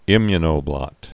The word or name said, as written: immunoblot